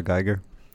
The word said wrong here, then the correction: Giger: Fun fact. It's actually pronounced Giger.